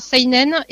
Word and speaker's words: seinen: Alors, seinen et shonen, en fait, c'est comme à l'anglaise, on prononce toutes les lettres.